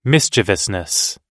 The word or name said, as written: mischievousness